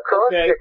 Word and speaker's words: acrostic: And I want to, is it acrostic? Is that how you pronounce that? – Yeah, acrostic is a poetic or a lyrical tool in which the first letter of each line spells out a message.